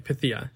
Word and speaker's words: Pythia: Is it Pythia or Pythia? – I think it's Pythia. – Okay. Because I remember we said it wrong the whole time. – Well, they also said it wrong. – That's my point. So. I'm like, everyone said it wrong the whole time, and then suddenly we got it right.